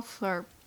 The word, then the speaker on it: Althorp: and the name of the estate was Althorp. And I probably said that wrong. – Althorp.